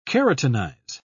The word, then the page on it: keratinize: British English